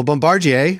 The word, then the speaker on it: Bombardier: Well, Bombardier, a French word which I pronounced correctly, and TELUS both reported strong earnings in Q4 of 2021 after selling parts of their businesses outside of their core focus.